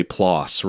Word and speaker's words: PLOS: but they say PLOS, rhyming with floss.